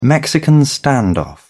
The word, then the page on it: mexican standoff: British English